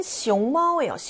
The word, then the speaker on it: 熊猫: 다음 C는 熊猫예요. 熊猫 판다라는 뜻이죠. 중국을 대표하는 동물입니다. 이성일성이죠. 자,